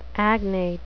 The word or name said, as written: agnate